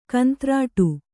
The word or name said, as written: ಕಂತ್ರಾಟ